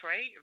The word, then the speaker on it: фэншуй: А по-китайски фэн – это ветер. Шуэй – вода, шуэй. – Шуэй, ага. Поэтому фэншуй даже вообще почти не меняется произношение.